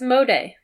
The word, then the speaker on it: Asmodee: I said how to pronounce. It's Asmodee. – Is it Asmodee? – Asmodee. – Mm-hmm. – Nice. – Oh, this is the American pronunciation. – Oh. – Asmodee.